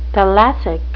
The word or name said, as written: thalassic